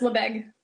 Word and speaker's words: Lebesgue: So if anybody does want to ask you about Lebesgue measurements, is that how you pronounce it? Lebesgue? – Yes, Lebesgue. – Yes, I got it right.